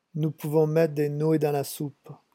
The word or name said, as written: nous pouvons